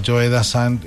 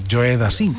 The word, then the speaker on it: Joe Dassin: Y entonces presentó a Joe Dassan como si fuera Joe Dassin, como se escribe. Joe Dassin. Entonces yo le dije, no olvides, Dianilla, que es Joe Dassan.